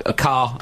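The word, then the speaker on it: Ker: That's Humphrey Kerr. Carr. I always get his name wrong.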